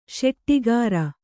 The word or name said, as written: ಶೆಟ್ಟಿಗಾರ